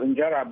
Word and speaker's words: injera: And I love the teff bread injera, how is it pronounced? – Injera.